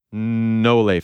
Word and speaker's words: Nole: I swear to you, I thought it was Noel fam. – But you said it, so good. – Yeah, say it again. – I didn't even say it right. – Properly. – Nole fam? – Yes. – Nole fam. – There you go.